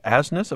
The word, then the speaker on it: Asness: Clifford Asness. Am I pronouncing that right? – Well, yes, you are.